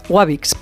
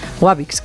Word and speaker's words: Wabix: Bueno, usted apunte, se pronuncia Wabix, pero es con W, ¿eh? Wabix, calcetines técnicos fabricados en España, aquí en Madrid, con todo el cariño del mundo.